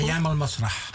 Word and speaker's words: Ayan al-Masra: En ook nu nog zijn jullie heel intensief betrokken bij Ayan al-Masra. Zeg ik het goed? – Ayan al-Masra. – Ah, zo moet het, ja. Zoals het in het Arabisch luidt.